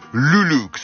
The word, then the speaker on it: Luluc: du nom de Luluk, ça s'écrit L-U-L-U-C.